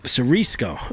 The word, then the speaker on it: Siriesco: Siriesco.